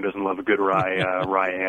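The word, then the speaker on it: Rei: Sox have got a couple good ones, they think, in Swihart and Vasquez, and yet still went out and got young Austin, I believe it's Ray. – It's actually pronounced Rye, which I only remember because of the alcohol, which is terrible.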